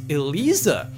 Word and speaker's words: Eliza: So Tekken 7's vampire name is pronounced Eliza.